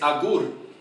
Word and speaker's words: Agur: Now you pronounce it Agur, I said Agur. What is the name in Spanish? – You know, I actually have a bilingual Bible, and in Spanish it's Agur. A-G-U-R.